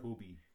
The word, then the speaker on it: Kobe: Like the beef. – It's Japanese? – No, well, he's not Japanese, but I'm saying that's how you pronounce it. – Cody Bryant. – Kobe.